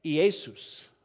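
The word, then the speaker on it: Iesus (Ἰησοῦς): Now, in Greek, the name Jesus is actually pronounced, I wrote it down because I wanted to get this right, Iesus.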